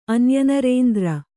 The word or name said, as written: ಅನ್ಯನರೇಂದ್ರ